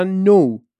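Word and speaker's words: know: تلفظ کردن حروف سایلنت حالا یعنی چی تمام حروفی که نوشته میشن تلفظ نمیشن باز شما باید چکار کنی بری فونتیک اون کلمه رو بخونی تا نخونی که متوجه نمیشی مثلا نو به معنای دانستن یا نی به معنای زانو خب یه دونه کی اولش داره که سایلنته یا نایف چاقو یه کی اولش داره که سایلنت.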